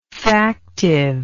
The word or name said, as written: factive